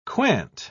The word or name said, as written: quant